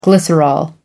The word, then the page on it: glycerol: American English